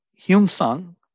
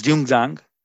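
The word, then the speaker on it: Xuanzang: And also that, interestingly, Hyunsang, or sometimes pronounced as Jungzang,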